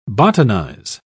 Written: botanize

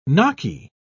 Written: gnocchi

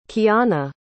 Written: Keanna

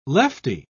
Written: lefty

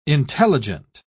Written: intelligent

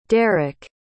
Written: derrek

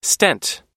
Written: stent

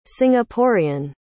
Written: singaporean

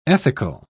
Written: ethical

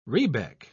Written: rebec

